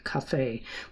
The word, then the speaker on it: café: Brahms was very fond of sitting with good friends over his beer or wine or his beloved café, with the accent, after the Viennese fashion, on the last syllable, in the Kneipe till the small hours of the day.